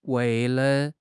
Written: wei4 le5

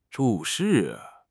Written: 注释

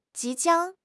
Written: 即将

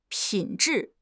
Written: 品质

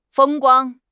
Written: 风光

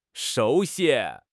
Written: shu2 xi1

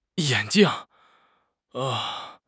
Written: yan3 jing4

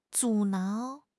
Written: 阻挠

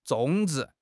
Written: 种植

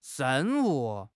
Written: ren4 wu5

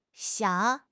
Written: xiang3

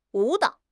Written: wu3 dao3

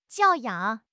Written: 教养

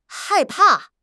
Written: hai4 pa4

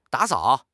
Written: da3 sao3

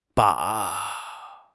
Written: ba3

